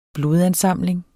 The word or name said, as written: blodansamling